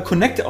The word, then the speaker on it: Kinect: Ja, nee, also wie gesagt, Kinect... – meint man, wenn man es liest. – Meint man, wenn man es liest. Aber nicht. – Phonetisch scheint das aber Connect ausgesprochen zu werden.